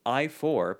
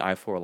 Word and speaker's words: Ayefour: The company name Retlaw, so R-E-T-L-A-W, which is just Walter backwards, as in Walt Disney. This one's pronounced I-4, but it's spelt A-Y-E-F-O-U-R. Obviously, I-4 like the highway.